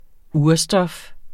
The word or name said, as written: urstof